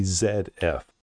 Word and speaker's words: Zf: Now, when you see the camera, when you see a picture of the camera, it has the letter Z and then a small F, but it's pronounced the ZF.